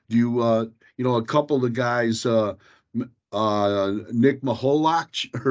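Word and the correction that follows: Mikolajchak: or I know I butchered that name. – Nick Mikulacic, yeah.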